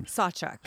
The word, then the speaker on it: Sawchuk: Sawchuk.